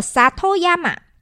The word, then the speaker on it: 里山: 其实，呃，它是日本的汉字，刚好就写成里山嘛，日语的发音就叫做 Satoyama。